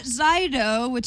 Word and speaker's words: Xydo: Zydo, that's how we think it's pronounced. It's XYDO, is launched today.